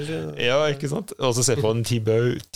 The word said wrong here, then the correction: Thibaut: Thibaut, Thibaut.